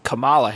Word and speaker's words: Kamala: It's because because because Senator Harris, she uh, her mom is is Indian, so the emphasis is mm. different. – She's Kamala Harris, Kamala mm. Khan is Kamala, so it's it's the difference between the first syllable and the second one.